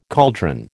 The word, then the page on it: caldron: American English